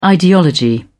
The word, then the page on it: ideology: British English